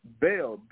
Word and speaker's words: Bel: And they, because the, uh, the Canaanites, they call them Baal, which is B-A-A-L, but the Armenians called him Bel, B-E-L.